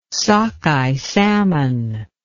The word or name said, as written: sockeye salmon